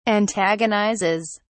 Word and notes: antagonizes: British English

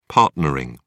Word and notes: partnering: British English

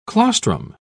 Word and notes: claustrum: British English

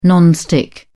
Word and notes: non stick: British English

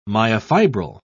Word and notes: myofibril: British English

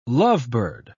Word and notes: lovebird: British English

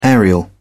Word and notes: aerial: British English

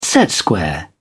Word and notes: set square: British English

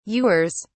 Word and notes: ewers: British English

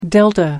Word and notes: delta: American English

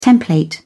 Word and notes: template: British English

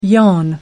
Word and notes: yawn: American English

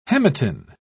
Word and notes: hematin: British English

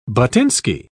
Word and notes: buttinsky: British English

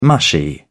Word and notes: mushy: British English

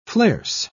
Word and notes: flares: British English

